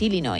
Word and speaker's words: Illinois: Pero es Illinois, o sea, si tú lo pronuncias bien y tienes buena dicción, es Illinois.